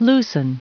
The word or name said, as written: loosen